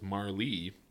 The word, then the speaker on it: Marle: Pronounce M-A-R-L-E. Jesus Christ. Game facts. How do you pronounce Marl? There's a whole fucking forum. Had a friend who insisted I was wrong, and the official correct pronunciation was Marlee. But then the one directly above it says the E is silent and it's Marl. Like Marl. M-A-R-L.